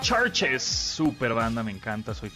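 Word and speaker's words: CHVRCHES: Acuérdense que Churches se escriben con, B, con B de vaca, en vez de la U, en vez de Churches, bueno, pues es C-H-B-R-C-H-E-S. Churches, la canción se llama Good Girls, la canción es nueva, nuevísima, de este 2021, de su álbum Screen Violence.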